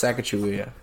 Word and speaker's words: Sacagawea: No, it's Sacagawea. – Sacagawea? Wait, what are you saying? – Sacagawea. – Sacagawea? – Yeah. – Sacagawea.